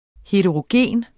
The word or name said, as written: HETEROGENT